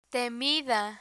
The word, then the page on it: temida: spanish